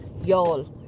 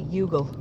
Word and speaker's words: Youghal: There's a place in County Cork called Yall. – Yeah. – And it's spelled Y-O-U-G-H-A-L. – Yeah. – And my grandmother used to say Yugle.